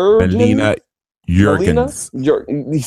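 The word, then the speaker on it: Melina Juergens: Her name is Melia. I'm sorry, I'm just butchering her name. I'm gonna just say MJ. M-E-L-I-N-A, and then her last name is J-U-E-R-G-E-N-S. – Melina Jurgens. – See, Bandit with the native of his homeland.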